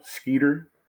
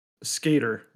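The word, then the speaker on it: Skator: And the other one is like Mosquito? Skeeter? – Skater, like a ice skater.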